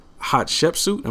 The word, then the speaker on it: Hatshepsut: Some more insight that you have is a book that you wrote, The Woman Who Would Be King, in Hatshepsut. Am I saying it right? – You said it right, yeah.